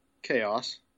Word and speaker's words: KAOS: The nemesis of Control is spelled K-A-O-S. How would you pronounce it? – Chaos. – Chaos. I've heard people call it Kaos numerous times. I'm like, how do you get Kaos from that? I'm pretty sure it's Chaos.